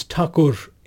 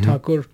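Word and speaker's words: Thakur (ঠাকুর): So Tagore's uh, name is Thakur in in Bengali, mm-hmm. Thakur. But the British, you know, when they started pronouncing it, they they they would pronounce it as Tagore. So over time, it became Tagore.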